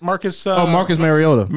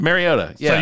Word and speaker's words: Mariota: Marcus Mariota. – Mariota. Yeah.